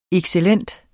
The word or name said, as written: excellent